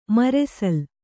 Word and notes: ಮರೆಸಲ್: maresal

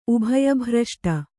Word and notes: ಉಭಯಭ್ರಷ್ಟ: ubhaya bhraṣṭa

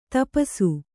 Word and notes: ತಪಸು: tapasu

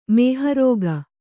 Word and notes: ಮಹಾರೂಕ: Kannada

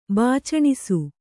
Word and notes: ಬಾಚಣಿಸು: bācaṇisu

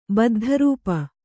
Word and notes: ಬದ್ಧರೂಪ: kannada

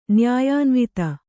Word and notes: ನ್ಯಾಯಾನ್ವಿತ: Kannada

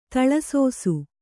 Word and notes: ತಲೆಸೇಸೆ: tale sēse

